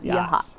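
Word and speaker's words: Yachats: Sounds Yehats. Like yachts. But Yehats.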